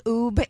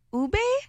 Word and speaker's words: Ube: People are saying Oob instead of Ube.